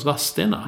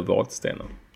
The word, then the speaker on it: Vadstena: Ja, jag tänkte precis fråga dig, varför uttalas, det stavas Vadstena, men det uttalas Vadstena. – Mm. – Det undrar sig. Jag säger, jag är då från, det är dialektalt. Jag säger Vadstena.